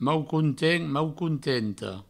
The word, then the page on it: maucontent: maucontenta; mau-countent; mau-countento